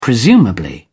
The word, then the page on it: presumably: British English